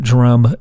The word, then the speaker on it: Drumb: That's off the Drumb EP, D-R-U-M-B, Dumb Drumb, Drumb.